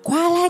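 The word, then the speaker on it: koala: Nhà của cậu ấy là một bệnh viện chăm sóc các bạn gấu túi Kalao, Kola, kalo. Đọc là qua la nhé đi Cậu mà đọc thế là ra thành nước ngọt Coca-Cola mất rồi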